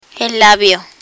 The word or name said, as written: labio